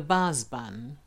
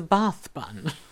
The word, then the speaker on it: bath bun: was called a baz bun. A baz bun, because Granny used to pronounce it as a baz bun. I only then found out that it was a bath bun.